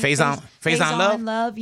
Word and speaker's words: Faison: I think one that really struck a nerve with me was Faison. Is that how you say his name? – Faison Love. – Yeah, because he's making fun of him and he's calling him out for not being a man, like man up type thing.